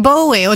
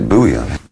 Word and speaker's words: Bowie: I always thought it was Bowie. I thought, well, it's a Scottish name, must be Bowie. But nobody in Scotland pronounces it like that. Pronounce it Bowie.